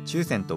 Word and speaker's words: 注染: 抽選手ぬぐいです抽選とは注いで染めると書いて抽選